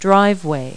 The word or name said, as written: driveway